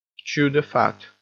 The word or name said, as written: chew the fat